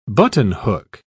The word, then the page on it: buttonhook: British English